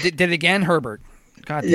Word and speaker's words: Herbert: It's Justin Herbert, right? It's Herbert, right. – Not Hebert. Yeah. All right. I just want to get Herbert. The pronunciation correct. I keep saying Justin Hebert, and I'm like, it's not.